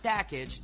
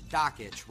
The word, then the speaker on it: Dakich: Not Dakich, Dockich, rhymes with Jockich.